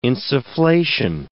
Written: insufflation

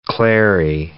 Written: clary sage

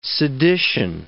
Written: sedition

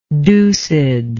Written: deucedly

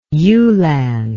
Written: yulan

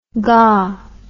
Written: gah

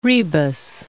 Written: rebus

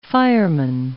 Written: fireman

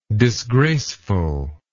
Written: disgracefulness

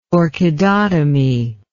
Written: orchidotomy

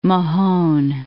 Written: Port Mahon

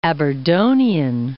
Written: Aberdonian

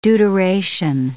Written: deuteration

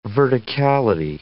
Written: verticality

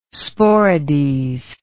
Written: Sporades

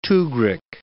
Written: tugrik

